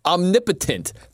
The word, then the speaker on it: omnipotent: And I started saying the word like Eminem was saying it, not knowing that it's omnipotent. And all these people were texting in and they're like, you're an idiot. That's not how you pronounce it. Get this moron off the air. And I'm like, what? What do you mean? What are you guys talking about? Turns out they were right. I mispronounced it.